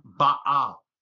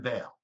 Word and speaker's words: Baal: And so, you know, in Hebrew, you say his name Baal, and we call him Baal, but it means the Possessor.